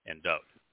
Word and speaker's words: Dod: And the wa is pronounced as either an O or the U sound, and so there's no da in Dod.